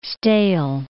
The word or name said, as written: stale